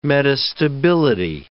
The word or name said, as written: metastability